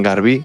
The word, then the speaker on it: Garbí: Y después os traigo un tema de una banda valenciana, de eh, un cuarteto que se llaman Garbí, con acento en la I.